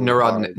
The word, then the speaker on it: Narodnik: responding to the Nerod- Narodnik's criticism of capital. I I'm butchering that word really. – Narodnik. Narodnik.